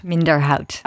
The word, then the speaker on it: Minderhout: Carlotta, I think I already mispronounced your surname. Minderhout. – Minderhout. – That's correct.